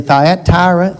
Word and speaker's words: Thyatira: This letter to the church of, Thyatira, I looked it up. It's actually four syllables. I guess some people could say Thyatira.